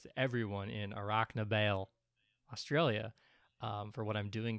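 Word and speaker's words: Warracknabeal: Yeah, so he was born in Australia in a town that I will now attempt to pronounce, Rocknabale. I think I might have gotten it that time. – That was pretty goddamn good. Rocknabale is what it looks like to me, and is probably how I will say it.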